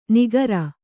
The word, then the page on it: ನಿಗರ: nigara